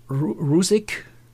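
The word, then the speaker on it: Ruzic: Neil Rutschitsch, ich hoffe, ich habe den Namen richtig ausgesprochen. – Also die Amerikaner sagen Rusik. – Okay. – Ist, glaube ich, ein osteuropäischer Ursprung der Name. Also als Deutsche würde man wahrscheinlich Rutzig sagen.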